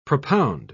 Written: propound

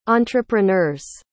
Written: entrepreneur's

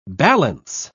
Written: balance

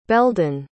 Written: beldon

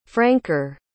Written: franker

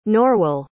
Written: norwel

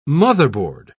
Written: motherboard

